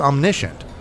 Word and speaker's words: omniscient: So another quality of God, the Father, and I mentioned it, I said it wrongly before, but it's omniscient, all-knowing.